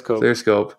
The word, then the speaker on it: Clearscope: So we've been experimenting with tools, ClearScape, I always forget how to pronounce it. – ClearScope last year.